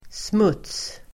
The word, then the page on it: smuts: [smut:s]